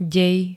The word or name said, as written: dej